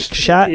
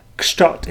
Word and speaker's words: Kszczot: Did I say his name right? Is that how you say it? Kszczot. Yeah, Kszczot.